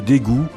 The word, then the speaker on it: Daegu: Dégoût s'écrit D-A-E-G-U et c'est une ville de Corée du Sud, un peu l'équivalent de Lyon.